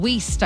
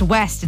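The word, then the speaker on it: Wiest: Her and Diane Weist, I think, is how you pronounce her name. – Yeah. – I don't think it's West. It's W-I-E-S-T.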